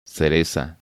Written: Cereza

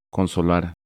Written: Consolar

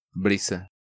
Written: Brisa

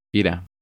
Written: Ira

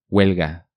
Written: Huelga

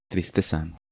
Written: Tristeza

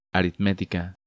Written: Aritmetica

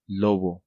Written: Lobo

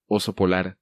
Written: Oso Polar

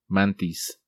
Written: Mantis

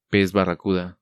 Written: Pez Barracuda

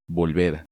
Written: Volver

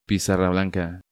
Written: Pizarra blanca

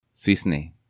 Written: Cisne

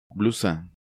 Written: Blusa

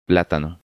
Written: Platano

